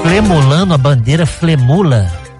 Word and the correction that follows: flamular: Como eu falei é flemula? Eu falei o quê? – Você falou flemulando. – Como é que fala? É flamular? – Flamular, tremular, balançar.